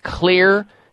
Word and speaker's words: Xlear: There's one called Clear Children's Nasal Spray, and Clear is spelled X-L-E-A-R.